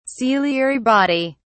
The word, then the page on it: ciliary body: British English